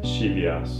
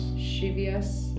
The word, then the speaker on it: Scivias: Um, remind me how to pronounce the Scivias. – Scivias. Yeah. Okay.